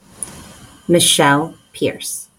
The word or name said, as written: Michelle Pierce